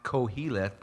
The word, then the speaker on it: Qoheleth: Pronounced koheleth, but spelled Q-O-H-E-L-E-T-H.